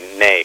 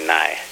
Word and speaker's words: ney: And I usually say ney, because that's the Turkish pronunciation of the instrument. You say nai.